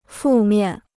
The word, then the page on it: 负面: fù miàn